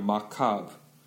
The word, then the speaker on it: macabre: It's spine chilling So I saw that movie was spine chilling That sound was spine chilling 이렇게 하시면 되고요 macabre 이 말은 그 마지막 re 묵음입니다 그래서 그냥 macabre 이라고 하시면 돼요.